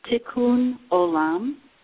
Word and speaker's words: Tikkun Olam: Tikkun Olam.